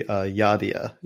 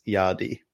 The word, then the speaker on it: Yadea: It's apparently Yadea is the correct English pronunciation, and apparently in Chinese it's Yadi.